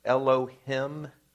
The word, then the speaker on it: Elohim: We see in this very first verse, God, and in Hebrew, that's pronounced Elohim, strong creator.